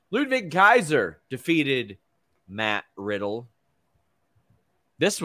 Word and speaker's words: Ludwig: Ludwig. – Yeah, but it's with a W, so it throws me up, and I want to say Wig instead of Vig.